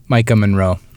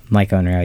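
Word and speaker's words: Maika Monroe: Micah Monroe. – Micah Monroe, yeah.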